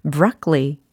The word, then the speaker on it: broccoli: 과연 영어적인 발음은 어떨까 궁금하신 적 있으시죠? 브로콜리 과연 영어로 어떻게 표현하면 좋을까요? 최대한 상상을 해보시고요. 나름의 발음을 한번 해보세요. 브로콜리는 영어로? 어, 네. 자, 한번 들어보십시오. broccoli, broccoli 이겁니다. 어때요? 브로콜리는 영어로 broccoli, 이렇게 해주셔야 되겠습니다.